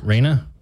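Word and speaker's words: Rhynna: Is it Raina? – Raina. – That's what we've been going with. – Okay. Because it's R-H-Y-N-N-A.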